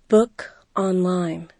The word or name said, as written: book online